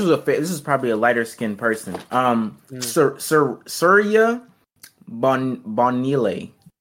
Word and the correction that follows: Surya Bonaly: Surya Bonaly, black French woman, did a backflip off of one leg in figure skating, and they said, whoa, that's dangerous as fuck.